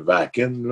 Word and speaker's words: Wacken: Euh, sont allés faire l'espèce de gros festival en Allemagne, le Wacken. Là. ça se prononce comme ça, là, mais tu sais, des, grosses affaires.